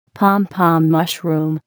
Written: pom pom mushroom

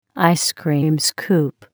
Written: ice cream scoop

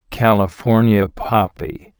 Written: California poppy

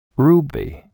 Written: ruby

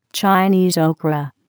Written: Chinese okra

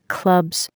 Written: clubs

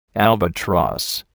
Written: albatross

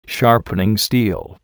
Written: sharpening steel

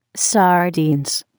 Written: sardines

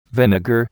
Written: vinegar

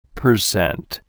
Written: percent

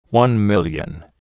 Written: One Million

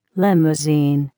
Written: limousine

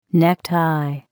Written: necktie

